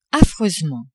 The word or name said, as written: affreusement